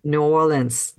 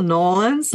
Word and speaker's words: New Orleans: You actually, the more it just slides into like one syllable, Nolans, like some people just say Nolans, like Nolans, and it's okay. That's when they know that you're local. – But the stress there is on the or part, isn't it?